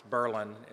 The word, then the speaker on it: Berlin: It's Berlin instead of Berlin, although it's spelled Berlin.